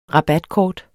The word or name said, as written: rabatkort